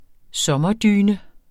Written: sommerdyne

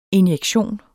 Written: injektion

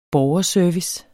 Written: borgerservice

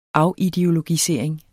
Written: afideologisering

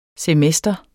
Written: semester

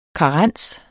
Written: karens

